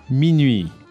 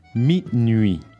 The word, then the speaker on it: minuit: À minuit. – So let's practice the pronunciation of the word for midnight. – Minuit.